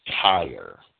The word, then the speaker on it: Tyre: they rhymed the word so that you would pronounce it as Tyre, T-Y-R-E. That's Kipling rhymes it with fire, so he has to be pronouncing it as Tyre.